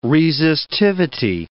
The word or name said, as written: resistivity